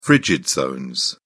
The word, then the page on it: frigid zone: British English